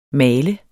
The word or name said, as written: malte